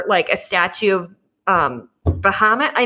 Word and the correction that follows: Baphomet: Baphomet. Baphomet.